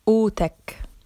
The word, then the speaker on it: قوتك: قوتك your strength. And notice here, as usual in Levantine Arabic, the qaf is silent.